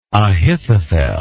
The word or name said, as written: Ahithophel